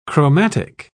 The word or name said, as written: chromatic